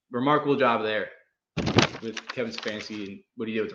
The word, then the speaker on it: Stefanski: Stefanski, yeah.